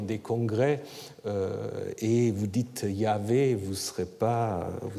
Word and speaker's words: Yahvé: Il y a des indications pour la prononciation Yahvé qui se trouvent surtout chez des pères l'Église.